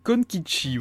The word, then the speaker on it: Konkichi: Konchiki, Konkichi, voilà. Alors ça s'écrit euh, C-O-N-K-I-C-H-I.